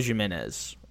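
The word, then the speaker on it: Jiménez: So Mike Trout, Aaron Judge, then Eloy Jimenez, or however you say his last name. I don't think I'm supposed to pronounce the J.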